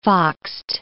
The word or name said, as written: foxed